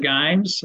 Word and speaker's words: Giem: You know, some people say Dean Giem. Some say Dean Giem. Call me Mr. Gimes. You know, whatever works. It doesn't bother me. – So not Giem. Okay. – That's the fancy pronunciation, and I'm not pinkies up.